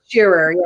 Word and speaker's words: Shearer: We are with Lindsay, and I'm gonna probably butcher your last name. Shearer. – Shearer, yes, close.